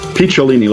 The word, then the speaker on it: Picciolini: I've been mispronouncing it, haven't I? – Picciolini, like a peach.